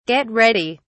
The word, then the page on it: get ready: British English